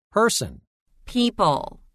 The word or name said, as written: Person – People